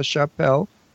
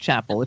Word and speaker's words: Chappell: And she said, no, it's Chappell, like a church. Don't you remember that dialogue? – It's not Chappell, it's Chappell. – I know that, but... But think of Chappell and just pronounce it French.